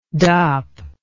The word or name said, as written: dop